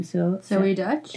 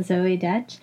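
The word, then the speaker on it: Zoey Deutch: Zoe Dutch. – Dutch, yeah. Yeah, Zoe Dutch. I was watching it, and you happened into the room right at the moment, I guess, where Zoe Dutch's character's name is Infinity, is talking about how she's gone zero waste, and that made you think about it.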